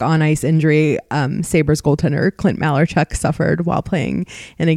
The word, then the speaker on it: Malarchuk: First thing he said was, it's pronounced Malarchuk.